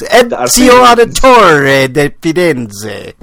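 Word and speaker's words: Ezio Auditore da Firenze: Izio. Diditor de Ferenc. – No, you gotta say it right. You gotta say it right. I'll do it for you, Breakman. It's Ezio Auditore de Firenze versus Ryu.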